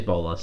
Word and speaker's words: bolas: Is that how you pronounce it? – It is bolas, yeah.